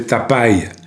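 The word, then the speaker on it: Tapy: À 23 ans, c'est un belâtre à l'œil vif qui se fait appeler Bernard Tapi, T-A-P-Y, à prononcer Tapai pour faire américain, épouse la chansonnette.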